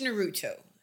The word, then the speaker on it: Naruto: really watched Naruto.